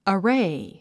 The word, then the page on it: array: əréi; アレイ